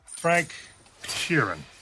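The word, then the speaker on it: Frank Sheeran: פרנק שירן, I saying that right? Yeah, you said right.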